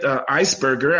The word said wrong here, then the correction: Eisgruber: I believe that's how you say his name. Eisgruber. – I beg your pardon, President Eisgruber. Mm-hmm. I got my G and my B confused.